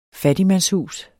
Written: fattigmandshus

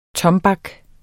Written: tombak

